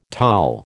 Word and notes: Taal: American English

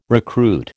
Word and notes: recruit: American English

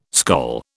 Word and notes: skol: British English

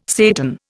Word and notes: Satan: Indian

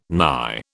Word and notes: nigh: British English

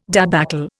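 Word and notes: débâcle: Indian

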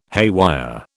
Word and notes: haywire: British English

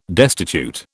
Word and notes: destitute: British English

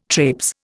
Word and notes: trapes: Indian

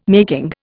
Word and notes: making: Indian

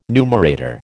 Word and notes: numerator: American English

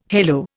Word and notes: halo: Indian

